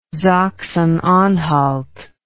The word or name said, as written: Sachsen-Anhalt